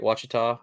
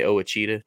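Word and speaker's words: Ouachita: I almost say that wrong because I make fun of it and say it wrong on purpose. I always say Owachita just to mess with people. – Owachita. – And I almost said that right then, but I got it right. Wachita.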